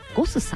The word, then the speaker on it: ゴス: な価格で提供するというコンセプトで、先ほどご紹介しました銀座にシフトレストランカザンさん、そしてすぐお隣にございますバーレストランゴスさん、 GOSS と書いてゴスさん、そしてお寿司屋さんが 2 店舗ございます。